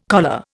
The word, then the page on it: choler: Indian